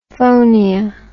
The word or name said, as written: phonia